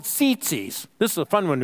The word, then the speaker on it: tzitzit: It's spelled T-Z-I-T-Z-I-T. Zit. Come on, say tzitzi with me. Come on, you're a teenager. You had the zits. Come on. Tzitzi. Tzitzis, okay?